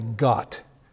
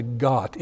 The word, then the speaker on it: gath: That large beam that we talked about, that's called a ghat. It's spelled G-A-T-H, and it's called a ghat.